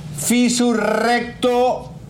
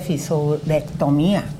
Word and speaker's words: fisurectomía: Fisurectomía mía. No, pero no se dice así, se dice fisurectomía.